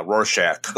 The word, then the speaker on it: Rorschach: Is that how you pronounce that? A Rorschach? – Yeah, Rorschach.